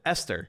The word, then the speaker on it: Ester: The H is silent. You're joking. – I thought you were kidding. – It's E S T E R is how you pronounce it. Esther. – You don't call someone Esther? – No. – Esther. Are you joking right now? – I mean, maybe some people to go by that. – No, nobody in the history of America has ever said Esther. – Esther. – No. E-S-T-E-R is how you pronounce that name.